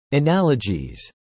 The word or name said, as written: analogies